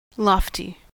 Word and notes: lofty: /ˈlɒfti/; /'lɒftɪ/; American English; /ˈlɔːfti/

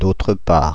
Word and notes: d'autre part: France (Paris); [d‿o.tʁə paʁ]

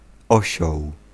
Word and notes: osioł: /ˈɔ.ɕɔw/

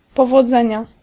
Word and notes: succes: IPA: [syk.sɛs]; Dutch

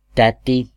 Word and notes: lady: /ˈleɪ.di/; American English

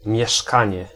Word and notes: demeure: France; /də.mœʁ/

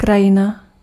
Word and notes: krajina: /ˈkra.jɪ.na/

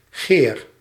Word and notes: spear: /spɪə̯(ɹ)/; /spɪɹ/

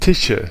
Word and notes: Tische: /ˈtɪʃə/

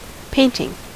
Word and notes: painting: /ˈpeɪn.tɪŋ/

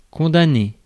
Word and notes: condamner: /kɔ̃.da.ne/

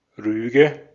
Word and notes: Rüge: /ˈʀyːɡə/

